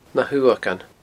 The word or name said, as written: nahuamhagan